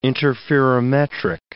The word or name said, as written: interferometric